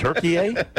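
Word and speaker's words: Türkiye: Does that mean at Thanksgiving we don't eat turkey, we eat turkey, eh? Who changed the pronunciation of the word? – Well, it's spelled differently, too. It's spelled T-U-R-K-I-Y-E, I believe now.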